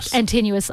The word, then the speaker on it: Antinous: Antinous.